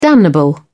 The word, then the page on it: damnable: British English